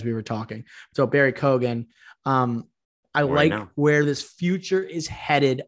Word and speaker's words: Keoghan: I am factoring some of my favorite performances from Makari and Barry Keoghan. I think that's how you pronounce it. It's actually Keoghan.